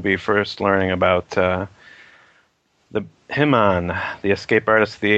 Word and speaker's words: Himon: I'm glad you pronounced it like that instead of Hyman.